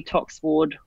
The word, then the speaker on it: Toxward: Or my Facebook page is my name, actually, Emily Toxward Writer. Well, that's a hard one. – Don't forget Toxward the W. – Name, yeah. Make sure you put the silent W in there that's not silent.